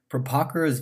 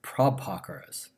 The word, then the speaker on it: Prabhakara: Prabhakara's views become important for a whole set of philosophers who follow him, and they become called Prabhakaras, with the first A, pronounced in a long way, Prabhakara.